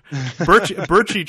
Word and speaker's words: Brcic: That's pretty close. There's many variations. Brcic, Brcic, Brcaci, whatever. – Brcaci. Brcic sounds more the way it's supposed to sound like. – It is, yeah.